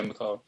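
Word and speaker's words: McAuley: Macaulay. Now, have I pronounced that right? Pascal's Angel, right? – Yeah, Macaulay.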